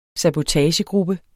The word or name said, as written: sabotagegruppe